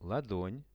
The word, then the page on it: ladon: Russian